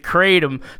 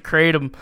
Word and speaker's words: Kratom: Like, I think in Malaysia, it's pronounced Kratom by a lot of people.